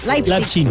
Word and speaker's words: Leipzig: Este Leipzig no se dice Leipzig. Leipzig. – Leipzig, en alemán. En alemán de Leipzig.